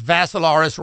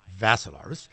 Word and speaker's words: Vassilaris: It's Dimitri with three I's, D-I-M-I-T-R-I. And the last name is Vassilaris, rhymes with Assilaris, but you add a V to it, thus Vassilaris.